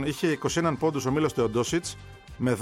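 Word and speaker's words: Teodosić: Γιατί μου λες Τεοντόσιτς. – Αφού έτσι το προφέρει.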